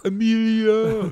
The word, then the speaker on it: Emilio: Emilio.